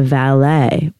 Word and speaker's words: Valais: But this all went down in one of the most famous Swiss winemaking regions, which is called the Valais.